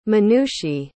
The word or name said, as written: Manushi